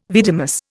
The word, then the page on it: vidimus: Indian